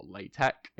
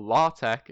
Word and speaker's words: LaTeX: I also write my dissertation in LaTeX, or LaTeX if you want to pronounce it correctly, or LaTeX if you want to be really Ooh. snobby about it. Ooh. But I say I say LaTeX.